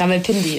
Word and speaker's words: Rawalpindi: Ja, war schon fast richtig. Rawalpindi ist, glaube ich, so ein bisschen schneller, ja.